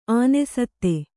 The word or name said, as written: ಅನಾಸ್ಥೆ